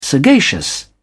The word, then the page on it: sagacious: British English